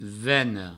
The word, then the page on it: vena: veno